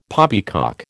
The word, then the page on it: poppycock: American English